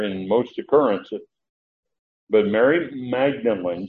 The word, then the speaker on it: Magdalene: Now you heard me pronounce it that way. Maybe you've heard people pronounce it Mary Magdalene. It's interesting, in the Greek, the last letter is neta, and it has an accent on it.